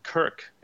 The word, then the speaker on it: Krk: So, you know, the island of Krk and the main town of Krk, this is spelled K-R-K, you know, one of these Croatian words that doesn't have vowels in it.